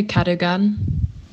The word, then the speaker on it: Cadogan: Also hier ist mal kurz die korrekte Aussprache. – I don't really know because it's a name, but I would say Cadogan. – Ja, also so wird es ausgesprochen.